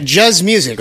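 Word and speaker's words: Juz Musik: We've got, now I want to make sure I say this right, Juz Music, right? – That's a fact, man.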